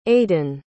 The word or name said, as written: Aaden